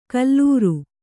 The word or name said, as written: ಕುಲೀರ